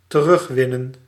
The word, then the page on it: recover: /ɹɪˈkʌvə/